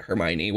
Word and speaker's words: Hermione: Because I didn't read it, Hermione.